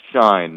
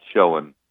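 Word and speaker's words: Schoen: By the way, people, it's pronounced shine, not showing.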